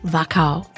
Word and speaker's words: Wachau: Wachau. Is that how you pronounce it? – Wachau, yep. – Wachau. – Mm. – W-A-C-H-A-U.